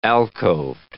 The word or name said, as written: alcoved